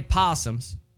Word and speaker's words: opossum: I don't like that you say opossum. – I'm sorry. Hold on. Hey, they possums. – They are. The O is silent.